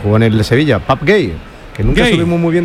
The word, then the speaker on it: Pape Gueye: Le pregunté y me dijo la pronunciación correcta, que es Pap Gay.